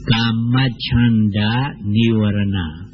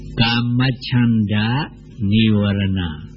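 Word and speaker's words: Kamacchanda Nivarana: You may find it difficult to pronounce and to remember. Kamachanda Nivarana. You should have knowledge of this Pali. Kamachanda Nivarana. please repeat after me, all of you.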